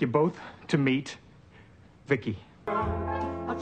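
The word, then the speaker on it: VICI: V-I-C-I, I, I pronounce it Vicky everyone, Angela Bowen here, the host of She's a Small Wonder, a Small Wonder podcast.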